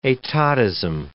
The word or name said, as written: etatism